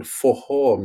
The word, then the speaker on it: forró: And she danced a type of music called forró music. Yeah. It's spelled forro, but double R in Portuguese, Brazilian Portuguese, you pronounce it like an H. So it's forró.